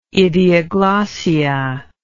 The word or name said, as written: idioglossia